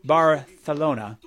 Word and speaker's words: Barcelona: By the way, Fontaine's DC has officially announced they'll be one of the headliners at the Primavera Sound Barcelona Festival, or Barthelona. That's how they say it there.